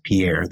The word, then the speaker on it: Pierre: So we volunteered for an open position in the Pierre, most people pronounce it Pierre, the capital of South Dakota, the Pierre Resident Agency in South Dakota, located in the central part of South Dakota.